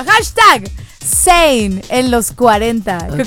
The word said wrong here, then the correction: Zayn: Es Zayn.